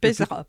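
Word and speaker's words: Peizerat: Ça, c'est peut-être le mal connu, malheureusement, et Gwendal Pézeria. – Pézera. – Qu'est-ce que je dis ? Pézeria. – Non, c'est Pézera. – Non, c'est Pézera, oui, il me semble.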